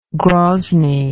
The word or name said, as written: grozny